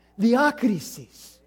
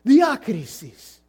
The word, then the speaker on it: diakrisis: Diakrisis. Well, I pronounced the D too hard. Diakrisis. George helps me all week with these things. Diakrisis.